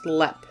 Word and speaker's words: Leap: And yes, it is spelled like the word leap, but it is pronounced Lepp.